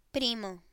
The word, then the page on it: primo: Spanish